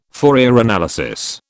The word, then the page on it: Fourier analysis: British English